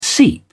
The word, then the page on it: seep: British English